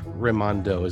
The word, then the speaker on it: Raimondo: I'm assuming that it's Raimondo. Is that correct? – That's correct.